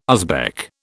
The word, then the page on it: Uzbek: British English